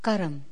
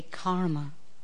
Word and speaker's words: karma: But the literal, if I was to pronounce it to you in Hindi, where it comes from, karam. Karam. But because you would pronounce, when you spell it in English, you would put the A's in it, we say karma. Actually, it's karam. And karam means, actually not karam, karma. So the R is half spoken.